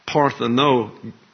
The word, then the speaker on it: parthenogenesis: Parthenogenesis is actually, it's a hard G in the Greek, but it's soft in the English.